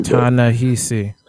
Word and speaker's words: Ta-Nehisi: Ta-Nehisi.